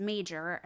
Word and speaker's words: Major: The spelling of his last name and mine was initially M-A-G-E-R. It's a German word meaning skinny. However, it's pronounced the same way as major, M-A-J-O-R, as my relatives would say major pronounced kind of like wager.